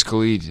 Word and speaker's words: Khalid: Is that how you say it? Khaled or Khalid? – I think it's Khalid, and then there's DJ Khaled.